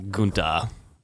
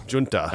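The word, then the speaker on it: Giunta: Best hair nominee right here in Furio Gunta. – Junta.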